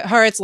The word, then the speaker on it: Haaretz: Anyway, I'm going to say it correctly this time. The Haaretz live blog says that Netanyahu is set to give an address to the Israeli public on Wednesday evening, so shortly.